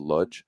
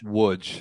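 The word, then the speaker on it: Łódź: I know she just signed a contract next year to go to Ludge. Vudge, sorry. Or how do you pronounce it? Ludge? – It's Wudge. Like the dub, the Wudge. L with a slash is kind of like a W.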